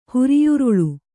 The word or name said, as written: ಹುರಿಯುರುಳು